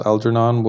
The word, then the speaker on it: Algernon: Well, this is how we're going to pronounce Algernon, because algebra and Algeria and all that shit. – True. That's some good evidence that it's a J. – Yeah, that's how I thought you would say it, given that, you know, the only words that I can think have that sequence of letters are pronounced J.